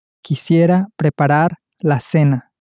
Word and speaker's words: preparar: Preparar. – Preparar. – You can hear two R's at the end there, so it can be a little bit difficult to pronounce.